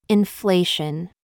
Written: FIFE

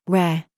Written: SQUARE

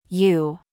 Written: YOU

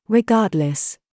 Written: KIT

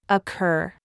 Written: SCHWA

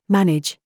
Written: TRAP

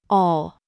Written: THOUGHT

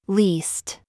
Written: FLEECE

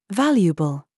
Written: VERVE

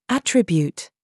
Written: RAW

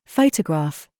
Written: GIG

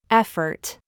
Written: SCHWA

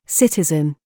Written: KIT

